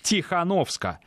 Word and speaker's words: Тихановская: Польский евродепутат Яцек Сариуш Вольский взял слово и потребовал от своих коллег произносить фамилию Тихановской в стенах Европарламента на польский манер. Тихановска.